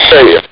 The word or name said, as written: say it